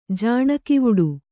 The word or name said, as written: ಜಾಣಗಿವುಡು